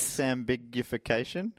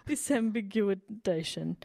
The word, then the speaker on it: disambiguation: Sambiguification? I've never said that word out loud before. – Sambiguation. – This is what I added a syllable. – Disambiguation.